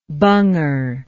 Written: bunger

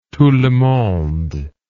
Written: tout le monde

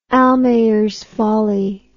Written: almayers folly

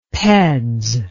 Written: peds

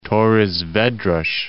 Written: Torres Vedras

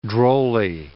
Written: drolly

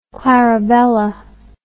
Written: clarabella

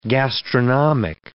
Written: gastronomic

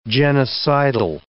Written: genocidal

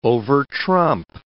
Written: overtrump

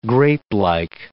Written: grapelike

